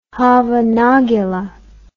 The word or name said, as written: hava nagila